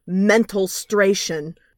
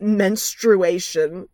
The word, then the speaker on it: menstruation: Johnny, during the song, pronounces it mentalstration, not menstruation. Okay.